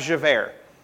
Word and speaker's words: Javert: You have Jean Valjean and you have Javert.